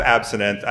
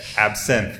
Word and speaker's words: absinthe: If I were to have absinthe, I mean, yes, absinthe. Thank you. – I'm just catching you. – No, please keep catching me because I'm horrible. That's how I think pronounced it my entire life because I did not know. – Mm-hmm. – And so now that I've corrected, I will need that. Absinthe. – Yeah, absinthe.